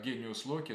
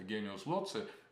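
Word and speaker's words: genius loci: То есть гений – это некоторое общее, некий вид, некий эйдос, который тоже имеет автономное существование, но он может жить как гениус Локи, например, вместе в каком-то гениус Лоци, это или Локи, по-разному произносится это С, латинское никто до конца не знает, как сами римляне, древние латиняне произносили этот звук, гениус Лоци, если как С, то это дух места.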